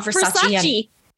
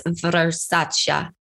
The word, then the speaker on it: Versace: It's not Versace. It's not Versace anymore. No. Donatello won't accept that. It's Versace.